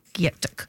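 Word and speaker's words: Gyetk: And you can follow Rachel Greening on Instagram to stay up to date, and you'll see beautiful images from the cookbook, which is spelt G-Y-E-T-K. G-Y-E-T-K. Gyatuk.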